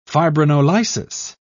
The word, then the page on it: fibrinolysis: British English